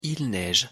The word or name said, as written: il neige